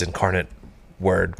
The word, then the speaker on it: Incarnate Word: Incarnate Wood? – Incarnate World? – Wood? – I believe it's Incarnate Word, but... – Word? – Yeah. – You got it. – Incarnate Word.